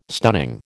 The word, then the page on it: stunning: American English